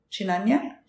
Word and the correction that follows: chinyanja: chinyanja yeah.